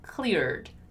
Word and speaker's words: cleared: And let's take the chance here to, once again, emphasize the pronunciation of the past form of the verb clear, which is cleared, cleared, cleared, with a D sound in the end, right?